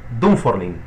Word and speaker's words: Dunfermline: Continuamos en compañía de Juan Carlos Rodríguez a propósito de su primera publicación, Los Quienes y las Ánimas de Dunfermline. Lo dije bien, ¿verdad? – Muy bien.